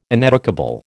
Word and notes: ineducable: American English

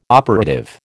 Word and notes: operative: American English

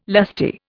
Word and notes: lusty: Indian

